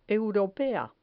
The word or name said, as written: europea